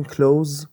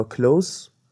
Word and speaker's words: close: مثل همین کلوز یا کلوز تو فرق صدای س و ز خودشو نشون میده